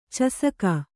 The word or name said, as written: ಚಷಕ